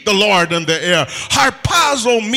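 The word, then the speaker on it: harpazo (ἁρπάζω): The Greek word translated rapture is pronounced harpazo.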